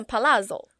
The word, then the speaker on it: palazzo: 它其实是念 palazzo。 – palazzo 听起来怎么觉得很像那个在爆米花就之类，就是噼噼啪啪的这样子，很可爱。